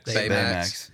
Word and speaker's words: Baymax: Baymax.